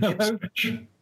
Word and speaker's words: Ipswich: Did I say that right, or did I just make a horrible... – No, Ipswich is my nearest reasonably large town.